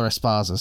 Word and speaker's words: Esparza: Now we've got Rose Th- Thug Rose Rose Namajunas versus Carla Esperanza, or Carla Es Carla Esparza. Sorry, I mispronounced that name, for the strawweight women's title.